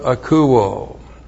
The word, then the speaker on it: akouo: And then we have the verb akouo. A-K-O-U long O. Akouo.